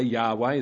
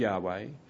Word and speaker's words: Yahweh: First of all, you'll see that they use that special name for God, the Lord, or Yahweh. We think that's how it's pronounced, but they didn't pronounce it for a long while because they were scared of misusing it. So we don't exactly know that that's the way it was pronounced, but let's say Yahweh is the closest we think we can get to it.